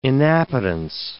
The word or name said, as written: inappetence